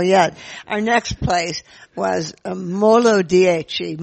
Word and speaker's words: Molo Dieci: And you need to be careful because Peter kept on calling it, because it's spelled out, M-O-L-O, and then the number 10. So I kept on calling it Molo 10, and I suddenly realized people had absolutely no idea what I was talking about.